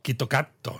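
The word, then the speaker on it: Kitto Katto (キットカット): A ver, ¿cómo se pronuncia Kit Kat en japonés? – Kitto Kat. – Kitto Katto, ¿no? Kitto Katto. Como con doble T.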